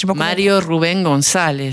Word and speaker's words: Mario Rubén González: Alors, son vrai nom, c'est Mario Rubén González. – Mario Rubén González.